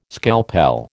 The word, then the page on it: scalpel: American English